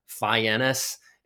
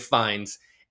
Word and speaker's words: Fiennes: But Ralph Fiennes, by the way, if you see his name, it looks like Ralph Fiennes. It's pronounced Ralph Fiennes.